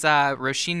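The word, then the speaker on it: Róisín: So this is Roisin Murphy. Oh, and by the way, if you're listening and you're figuring out how to Google it, her name is spelled O-R-I-S-I-N. Yeah. That's Irish Roisin.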